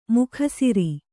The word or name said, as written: ಮೇಗುಸಿರ್